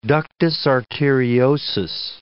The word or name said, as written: ductus arteriosus